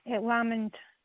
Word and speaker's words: Lamond: Lamond.